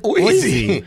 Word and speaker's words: OZY: I don't know if you pronounce it Ozzy or OZ or OZY. But whatever Oizy. – It is. – Oizy.